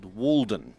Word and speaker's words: Wald: Tonight's work uses the first four letters of that title, though it's pronounced Wald, the German word for woods or forests.